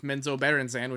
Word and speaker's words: Menzoberranzan: Last episode, I questioned whether or not Drows of Menzoberranzan, which is how you pronounce it, had princes or princesses, and this chapter announces that they do, clarifies that they do.